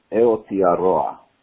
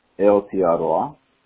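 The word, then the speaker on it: Aotearoa: Nothing really having to do with Jewish knowledge, but it is pronounced Eotearoa. Okay, New Zealand, the actual name of New Zealand that the Maoris called it is Eotearoa.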